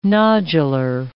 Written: nodular

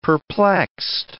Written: perplexed

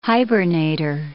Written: hibernator

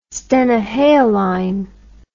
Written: stenohaline

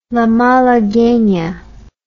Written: la malaguena

